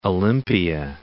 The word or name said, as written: Olympia